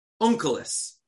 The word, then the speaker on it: Unkelos: The fellow's name was Unklus. Unkelos. In Hebrew, in English, it's spelled U-N-K-E-L-O-S, Unkelos.